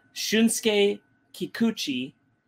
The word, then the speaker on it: Shunsuke Kikuchi: Shunsuke Kikuchi is the, and I checked that. That's how you pronounce it.